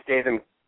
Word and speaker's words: Statham: As well as, I liked, what is his name, Jason Statham, or Jason Statham, I forget how to pronounce his last name. – Statham, yeah.